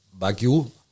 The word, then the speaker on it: Wagyu: Also aussprechen tut man es eigentlich Wagyu. – Mhm. – Also Wagyu, Wagyu sagen die ba Japaner.